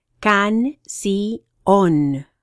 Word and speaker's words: canción: Canción, song. You pronounce it can-si-on. Miércoles, Wednesday.